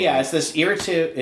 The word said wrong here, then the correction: iterative: it's this, God, I can't pronounce iterative. – It. Right. Iterative, thank you.